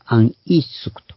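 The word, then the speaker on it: 安 イ ス ク (안이숙): 安利淑と書いて、安イスクと、安イスク。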